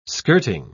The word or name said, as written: skirting